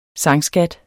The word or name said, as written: SANGSKAT